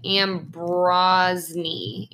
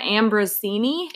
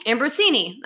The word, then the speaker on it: Ambrosini: It's like Ambrosny. – Oh boy. – Look it up. You're better at pronouncing things. – Melissa Ambrosini. – Ambrosini.